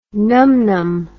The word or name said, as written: numnum